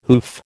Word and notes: hoof: American English

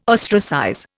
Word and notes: ostracize: Indian

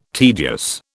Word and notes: tedious: British English

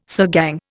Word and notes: sirgang: Indian

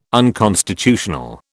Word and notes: unconstitutional: British English

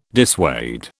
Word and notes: dissuade: British English

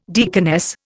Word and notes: deaconess: Indian

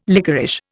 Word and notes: lickerish: Indian

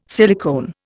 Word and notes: silicone: Indian

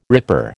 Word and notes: ripper: American English

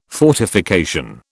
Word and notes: fortification: British English

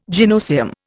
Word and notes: gynoecium: Indian

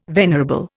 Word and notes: venerable: Indian

